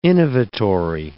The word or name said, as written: innovatory